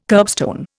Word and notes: kerbstone: Indian

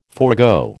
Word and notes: forgo: American English

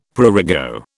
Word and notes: prurigo: British English

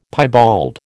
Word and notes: piebald: American English

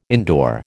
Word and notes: indoor: American English

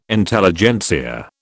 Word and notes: Intelligentsia: British English

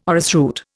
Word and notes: orrisroot: Indian

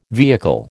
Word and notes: vehicle: American English

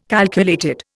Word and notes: calculated: Indian

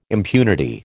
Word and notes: impunity: American English

